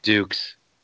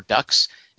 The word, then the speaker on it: Dux: But his name is Dukes. – Anime for white people. – No, it's Dux. What are you talking about? D-U-X, Dux. Rhymes with Lux, the detergent.